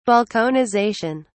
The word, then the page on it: balkanization: British English